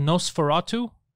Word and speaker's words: Nos4A2: And there's a show called Nosferatu. You remember the vampire Nosferatu? But it's spelled like a license plate, like Nos4A2.